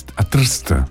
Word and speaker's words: Trieste: Dicono che sia a Trist, come dicono gli slavi, questa è la pronuncia.